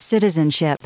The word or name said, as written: citizenship